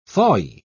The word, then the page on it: thawy: British English